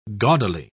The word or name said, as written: gaudily